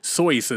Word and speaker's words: Seuss: Not, and that's not even how you pronounce his name, by the way. It's Soyce or some shit like that, but people said Sue, so he just stuck with it.